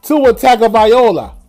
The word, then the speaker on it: Tua Tagovailoa: The Miami Dolphins got Tua, Tabagalua, Tabavalua. Who can pronounce this kid's name for me? Can anybody pronounce this kid's name? Tua Tagovailoa. Tua Tagovailoa like Rick Ross said, Tua Tagovailoa. I think that's the right pronunciation.